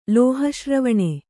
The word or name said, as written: ಲೋಹಶ್ರವಣೆ